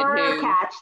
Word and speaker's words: Hurkacz: Yeah, I think it's Hurkacz. Yeah, the Polish kid. – Hurkacz, that's it.